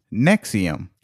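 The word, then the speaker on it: NXIVM: but no, it's actually pronounced Nexium.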